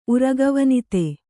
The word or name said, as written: ಉರಗವನಿತೆ